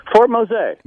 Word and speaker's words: Fort Mose: Tell us about, is it Fort Mose? Is that how you pronounce it? – Fort Mose.